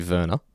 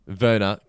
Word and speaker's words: Werner: Werner Klemperer, I hope I pronounced that correctly. – I don't think you have. Yeah. Firstly, I'm pretty sure that it's going to be Werner. – Werner Klemperer.